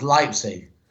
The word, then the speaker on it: Leipzig: It's pronounced Leipzig, because E and I in German is pronounced I, and I and E is pronounced E.